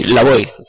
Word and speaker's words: Slavoj: El señor Žižek, ¿y es Slavoj o es Slavoj? Slavoj.